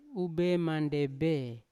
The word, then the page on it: ube mandebe: Yawa